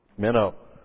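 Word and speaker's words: Menno (μένω): In fact, it uses a word Menno, M-E-N, long O.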